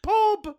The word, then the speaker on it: Pob: Pob. – Yes, I know. We reference pob for no reason. – No, because it rhymes with gob and...